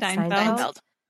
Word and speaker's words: Steinfeld: Steinfeld.